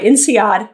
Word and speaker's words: INSEAD: INSEAD is how you pronounce it? – That's right. INSEAD.